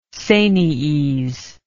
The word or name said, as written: sanies